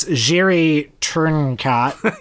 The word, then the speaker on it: Jiří Trnka: But it is Jiří Trnka. – Yep. – That's Trnka.